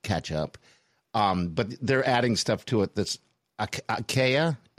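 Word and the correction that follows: açaí: Is that, am I saying that right? A-C-A-I? – Oh, acai.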